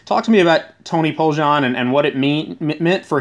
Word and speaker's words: Poljan: Is that how you pronounce his name? – Yeah, Tony Poljan.